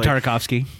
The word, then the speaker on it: Tartakovsky: By the way, just so you know, if he did fight General Grievous in the Genny Tartakov... I can't ever pronounce the guy's last name properly. – Genny Tartakovsky.